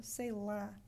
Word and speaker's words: sei lá: So say la instead of say law.